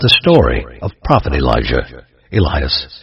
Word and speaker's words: Elias: Let us now go back to the topic of Prophet Elijah, whose name is pronounced Elias in both the Arabic and the Roman languages.